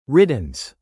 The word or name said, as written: riddance